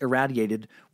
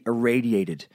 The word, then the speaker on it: irradiated: "You pronounced irradiated with a rad, like I just did right now. It's supposed to be irradiated.